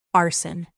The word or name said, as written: arson